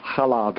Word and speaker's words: challah: It's Jewish, you have to do a little ch. – Yeah, challah bread.